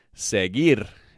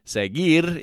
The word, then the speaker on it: seguir: Okay, now the verb of the day is seguir, and seguir means to follow. Now, seguir is spelt S E G U I R, seguir.